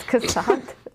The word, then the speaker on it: Cassatt: Do you not say it, Mary Cassatt? Have I been saying it wrong? – That's a tape. – It's Cassatt. Mary Cassatt. Yes. No, I'm legitimately asking. – I'm offended. No, it is. I said it wrong in an art history class and got made fun of. So, yes, oh. I am pretty sure it's Cassatt.